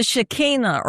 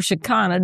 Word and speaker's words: Shekinah: The Shekinah or Shekinah, depending on how you want to pronounce it.